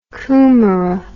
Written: kumara

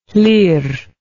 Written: Llyr

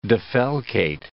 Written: defalcate

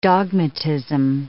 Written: dogmatism

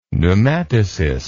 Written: pneumatocyst

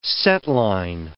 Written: setline

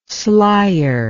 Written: slier

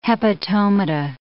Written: hepatomata

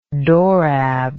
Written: dorab

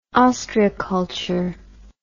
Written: ostreiculture

